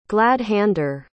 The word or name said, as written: glad-hander